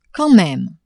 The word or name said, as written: quand meme